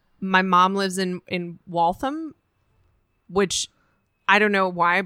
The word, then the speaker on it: Waltham: Waltham. – Waltham. And it's W-A-L-T-H-A-M. Mm. And I always want to overpronounce Waltham. – It. And be like, Waltham. Waltham.